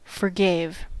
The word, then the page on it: forgave: American English